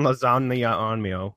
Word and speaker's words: Leguizamo: John Leguizamo.